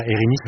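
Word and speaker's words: Érinyes: Ah, les Irini, vous ne connaissez pas, Irini, ça s'écrit E-R-I-N-Y-E-S.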